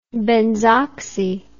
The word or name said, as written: benzoxy